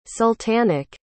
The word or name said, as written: sultanic